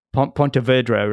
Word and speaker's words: Pontevedra: So you won that at home in Pontevedra, right? Is that how, did I pronounce it right in Spain? – Yeah. Very good.